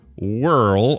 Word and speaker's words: Whirl: Frank Whirl, or he says not unpronounceable, just think Whirly Bird.